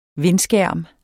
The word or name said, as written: vindskærm